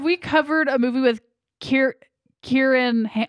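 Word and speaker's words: Ciarán: His name is pronounced Kieran. – Kieran, okay. – Like Kieran and Kieran Culkin.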